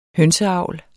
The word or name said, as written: hønseavl